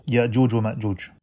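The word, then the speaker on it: Yajuj wa Majuj: However, they are pronounced in the Arabic way as well, Ya'juj wa Ma'juj.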